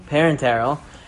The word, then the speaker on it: Parenteral: Parenteral. – Those ones are what we would call central lines. Those usually have TPN, and those are specifically catered. The question is not going to be the parenteral,